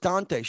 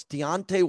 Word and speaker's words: Deontay: I said, what's his name? DeAndre or Dante? I thought it was Dante. She said, no, it's Deontay Wilder.